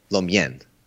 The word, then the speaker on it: mein: Americans say main, like you say chow mein. – Yeah, chow mein, lo mein. – But it's actually lo mien, chow mien.